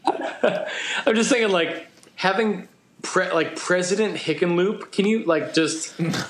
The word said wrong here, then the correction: Hickenlooper: It's Hickenlooper. – Yeah, there's even more syllables.